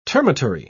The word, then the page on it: termitary: British English